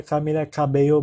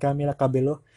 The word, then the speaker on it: Camila Cabello: Dan kalau kalian tahu Camila Cabello, bacanya Camila Cabello bukan Camila Cabello.